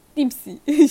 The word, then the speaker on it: Dempsey: Dempsey mi diyor? Nasıl okunuyor efendim. Dempsey. – Bence. – Dempsey.